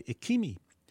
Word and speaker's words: Ekimi: It's called Sing Out Christmas Time Is Here by Ikimi. Ikimi is spelled E-K-I-M-I, and it's two fellows, Billy Larkin and Chris Dahlgren, whose talents collided in the universe in an area around Cincinnati, Ohio.